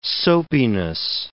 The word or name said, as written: soapiness